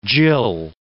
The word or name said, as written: gill